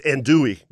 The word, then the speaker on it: andouille: No, there's no L. It's andouille.